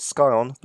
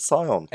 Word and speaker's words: Scion: And instead, what we have here is not Scion, but Scion. – S-C-I-O-N.